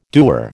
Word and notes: doer: American English

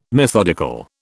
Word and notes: methodical: British English

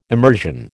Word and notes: emersion: American English

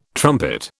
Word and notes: trumpet: British English

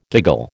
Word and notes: giggle: American English

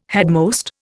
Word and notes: headmost: Indian

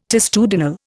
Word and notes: testudinal: Indian

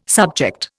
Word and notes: subject: Indian

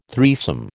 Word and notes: threesome: American English

